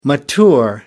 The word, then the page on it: mature: American English